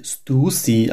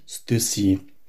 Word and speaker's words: Stussy: Lag ich all die Jahre falsch mit der Skateboard-Markenaussprache Stüssi? Ja. Indes die englische Wikipedia und das restliche Internet entgegen der Fargo-Aussprache sagen, die Marke werde Stussy ausgesprochen, jedenfalls nicht Stüssi.